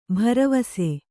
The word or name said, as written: ಭರವಸ